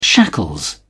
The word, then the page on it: shackles: British English